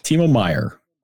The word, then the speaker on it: Timo Meier: Timo Meier, I got the name right, correct? – Timo Meier.